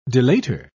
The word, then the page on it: delator: British English